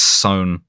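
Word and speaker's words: Son: Apparently, it's Sohn, like rhymes with bone.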